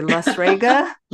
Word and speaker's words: La Strega: So if I'm even pronouncing it correctly, La Strega? – La Strega, yeah.